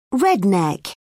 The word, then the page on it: redneck: British English